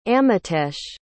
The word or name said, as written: amitesh